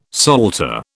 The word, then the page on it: salter: British English